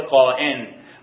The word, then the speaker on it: قائن: در کتاب مقدس قابیل به شکل قائن با تلفظ و نگارش قائن، قاف الف همزه نون، به شکل قائن و همزه روی قائن، مثل قائد، مثل قائم، قائن که بعدن قابیل مشهور شده در قرآنم که اصلا سبت قرآن نیست که این اسما رو بیاره.